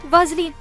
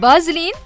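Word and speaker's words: Vaseline: في صانعة محتوى كورية نزلت فيديو تتكلم فيه عن منتجات فازلين، لكن طريقة نطقها للاسم يعني أقرب لفازلين بالبي، وهني الانترنت اشتعل.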